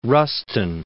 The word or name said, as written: Rustin